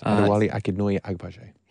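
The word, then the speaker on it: Adewale Akinnuoye-Agbaje: Adewale Akinoye Agbaje.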